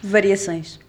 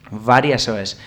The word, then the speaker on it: Variações: Y para ello vamos a preguntarle por un icono de la música portuguesa que va a sonar a continuación, como es Antonio Varasoes. No sé si lo he dicho bien. – Variações. – Variações.